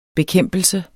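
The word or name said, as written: bekæmpelse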